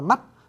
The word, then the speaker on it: mắt: Không đúng quý vị, chữ biết này ở trên xứ Mường đọc là mắt, à, M-A-T mà không có dấu sắc quý vị nhé.